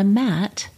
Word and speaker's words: matte: I mostly reach for a traditional or even a matte lipstick. – Matt wears lipstick? – M-A-T-T-E. Matt. – Oh, Matte. – Yeah. Was that how you pronounce it? Okay, good.